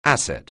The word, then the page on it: asset: anglais